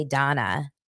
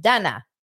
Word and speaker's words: Dana: If you say Dana, they immediately spell it D-A-N-A because it's Dana. Dana.